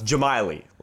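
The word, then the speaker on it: Džemaili: Montreal Impact beat DC United 2-0, which, by the way, finally we know how to pronounce Blairim Jamiley's name. I was very confused about how to say it. Now it's Jamiley.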